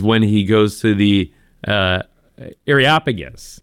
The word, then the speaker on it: Areopagus: The Areopagus, the Areopagus.